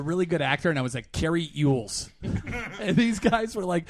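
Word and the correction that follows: Elwes: And how do you pronounce his last name? – Elways. – Is it Elways? Yeah. I always thought it was Ewells, too.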